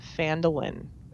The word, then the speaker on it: Phandalin: And In Volo's, Volo's, Wake takes place um, at first in the town of Phandalin, if I'm pronouncing that correctly.